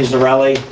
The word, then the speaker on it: Zarrilli: Anthony Zarelli. It's spelled Z-A-R-R-I-L-L-I.